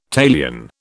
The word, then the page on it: talion: British English